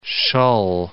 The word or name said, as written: Shull